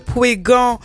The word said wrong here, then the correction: Pouéignan: Ou non, Pouéignan. – Pouéignan.